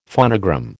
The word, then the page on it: phonogram: American English